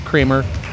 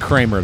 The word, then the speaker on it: Kremer: On each episode of Who Charted, Howard Kremer, say I don't know how to say his name, K-R-E-M-E-R, Kremer. – Okay. – You'd think Kramer, though, because of Kramer versus Kramer.